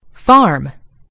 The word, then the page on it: farm: British English